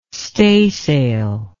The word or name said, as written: staysail